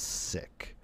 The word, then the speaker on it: Sikh: And the first shot was, the guy that's interviewing is asking a sick guy, so how do you pronounce it, Sikh or sick? And he just looks at the camera. He's like, it's sick, which I thought was cool.